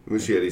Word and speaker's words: Muschietti: Muschietti sounds right.